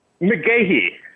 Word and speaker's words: McGahey: Marcus, did I butcher your surname, man? How do you pronounce it? – McGahey. – McGahey. See, I had a fraternity brother, McGahey, and he spelled it like Willis McGahey. So I should have just gone McGahey.